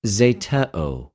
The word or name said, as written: ζητεω